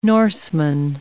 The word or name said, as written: Norseman